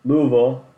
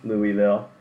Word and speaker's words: Louisville: But yeah, Louisville, as I've learned that it's pronounced, not Louisville.